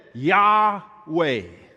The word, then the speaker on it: Yahweh: The word Lord, often in English text, is capitalized, where it is written in Hebrew, these four-letter words, YHWH. Okay? We ascribe those American alphabet letters to that, and we pronounce it Yahweh.